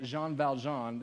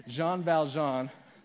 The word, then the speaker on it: Jean Valjean: So Les Mis, if you don't know the story, there is this criminal named Jean Valjean. Did I say that right, Graham? No, okay, thank you. Jean Valjean.